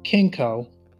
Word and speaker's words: Kencko: So I discovered this company called Kencko, and it's spelled K-E-N-C-K-O,